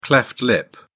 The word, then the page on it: cleft lip: British English